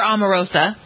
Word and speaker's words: Omarosa: Amoroso, one of the... – Or Omarosa.